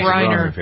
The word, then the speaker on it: Griner: I always pronounce Griner. – It wrong. Griner.